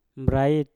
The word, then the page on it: brait: Mpur